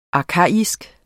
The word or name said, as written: arkaisk